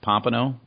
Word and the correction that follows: Pamplona: is it Pompano, Spain? – Pamplona.